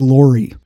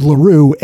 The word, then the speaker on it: LaRue: I pronounced it Laurie, L-A-U-R-I-E, and it's actually LaRue, L-A-R-U-E.